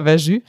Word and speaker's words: Verjus: Also letztes Jahr war ich am Wurstmarkt dabei bei der Berichterstattung und da wurde uns ganz doll angepriesen, so ähm, ich will es jetzt nicht falsch aussprechen, Verjus oder Verjus. – Ja, Verjus.